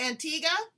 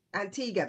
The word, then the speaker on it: Antigua: I'm saying it wrong. It's Antigua. – Antigua, that's how we pronounce, yes, Antigua.